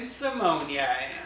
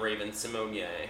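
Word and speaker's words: Raven-Symoné: I have never heard somebody pronounce her name Raven Simonie. – And she seemed to acknowledge that that's the correct way to pronounce it.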